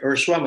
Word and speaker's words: Ursua: My name is Rick Ursua. My last name is spelled as U-R-S-U-A.